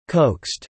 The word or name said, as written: coaxed